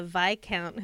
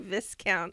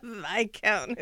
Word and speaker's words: Viscount: That reminds me, when I was reading these books, and the first one I read was The Viscount Who Loved Me. From watching the Netflix series, mm-hmm. I've learned that's the correct pronunciation, yes. Because in my head I was saying Viscount.